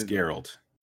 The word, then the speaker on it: Geralt: It's Geralt. It's a hard G.